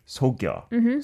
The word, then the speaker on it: 속여: So you added another phantom ghost syllable there, Benny. But it should just be 귀신을 속여.